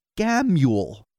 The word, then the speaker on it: Gammel: Our last name is pronounced Gamel, pronounced like glam without the L, and then Yule. Gamule.